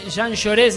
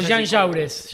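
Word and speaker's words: Jean Jaurès: Jean Lloré se pronuncia. – No intriga, siempre que veo esa dirección. – O Jean Jaures.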